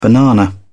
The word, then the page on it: banana: British English